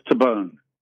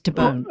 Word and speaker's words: Tabone: Paul's Tabone. – Oh, it is Tabone. – I guess if it's Italian, it'd be Tabone.